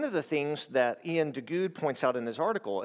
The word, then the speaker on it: Duguid: Then you have an individual named, and I've mispronounced his name in the past, but I think it's Ian Duguid. I wanted to say Duguid because it's D-U-G-U-I-D, but it's Ian Duguid.